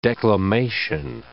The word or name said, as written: declamation